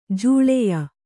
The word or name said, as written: ಜೋಳಯ